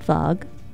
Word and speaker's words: Vog: We're called Vog, S of the V. We rhyme with dog. That's how you remember how to say it, because it's spelled V-O-G, and you know, if you pronounce the O with the line over it, that's like Vogue.